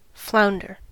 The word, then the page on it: flounder: American English